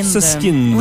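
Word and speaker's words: Süskind: De Patrick, comment se prononce son nom? – Soskind. – Oui, donc, Soskind.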